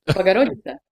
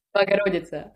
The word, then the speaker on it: Богородица: Богородица. – Say it again. – Богородица.